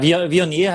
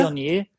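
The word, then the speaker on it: Viognier: Ja, Vionier heißt das. – Vionier. Vionier.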